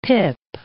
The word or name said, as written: pip